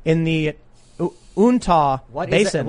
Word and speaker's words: Uintah: It's Uintah. Oh, that's how that's pronounced.